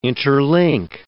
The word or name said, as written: interlink